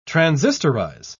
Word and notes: transistorize: British English

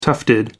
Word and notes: tufted: American English

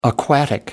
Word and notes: aquatic: American English

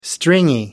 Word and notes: stringy: American English

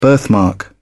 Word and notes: birthmark: British English

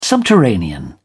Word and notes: subterranean: British English

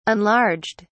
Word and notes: enlarged: British English

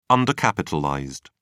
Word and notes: undercapitalized: British English

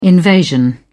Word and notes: invasion: British English

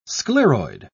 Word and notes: scleroid: British English

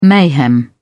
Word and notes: mayhem: British English

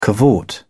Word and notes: cavort: British English

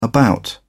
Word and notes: about: British English

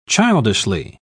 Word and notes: childishly: British English

